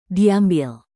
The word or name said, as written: diambil